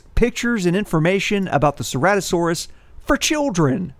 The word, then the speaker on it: Ceratosaurus: You pronounce their name Ceratosaurus.